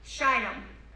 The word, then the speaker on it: Shittim: Shittim. Don't mispronounce that. It's a long I.